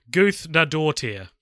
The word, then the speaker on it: Guðnadóttir: Her name is pronounced Hilda Guðnadóttir. There you go. – Sweet. – it is not spelt Guthn. I've never looked at it until now. Yeah. It is not spelt that way, so that is confusing. – Yeah, well, it's got one of those letters that I guess is pronounced TH, but it does not look like that.